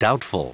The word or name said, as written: doubtful